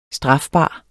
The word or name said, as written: strafbar